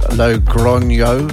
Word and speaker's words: Logroño: Currently in Logroño, pronounced Logroño.